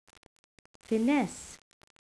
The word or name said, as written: finesse